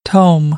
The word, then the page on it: tome: American English; oʊ